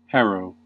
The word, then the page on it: harrow: American English